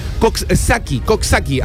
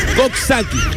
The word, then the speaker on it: Coxsackie: Uno de ellos es el Coxsackie, así es la pronunciación. Si me equivoco, me dices, ¿eh? Coxsackie.